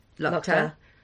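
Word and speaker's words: Luchta: I'm just using Ella Young's pronunciation yeah. – there. It is Govnu, Lochta, Kretna.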